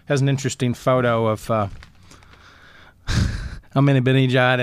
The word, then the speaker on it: Ahmadinejad: But Ahmadinejad.